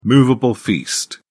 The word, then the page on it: movable feast: British English